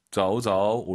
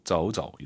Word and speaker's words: 走走: 대신 두 번째 동사는 경성이에요. 한 글자일 때. 走走 올리지 마시고, 走走 이렇게 발음하시면 좋을 것 같습니다.